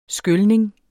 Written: skylning

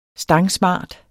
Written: stangsmart

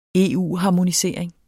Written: EU-harmonisering

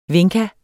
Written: vinca

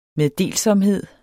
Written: meddelsomhed

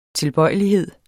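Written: tilbøjelighed

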